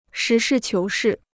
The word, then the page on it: 实事求是: shí shì qiú shì